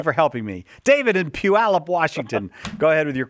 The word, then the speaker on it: Puyallup: Second, how do you say the name of your town? – Puyallup. It's an Indian name. – Oh, Puyallup.